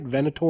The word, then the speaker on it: Venator: Venator FC, is that what it is? – Nailed it.